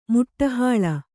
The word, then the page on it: ಮುಟ್ಟುಹಾಳ: muṭṭuhāḷa